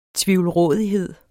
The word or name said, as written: tvivlrådighed